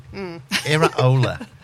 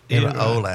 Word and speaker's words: Iraola: Really, and this is how you pronounce it. – Iraola. – What? – Iraola. It's a second name.